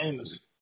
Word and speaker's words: Amos: J-A-C-K? – No, it's Amos. – Okay, A-M-O-S. Okay. How do you spell it? – A-M-O-S.